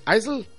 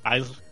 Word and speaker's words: Isle: Pero, ¿cómo se dice en inglés? Isle. – Isle.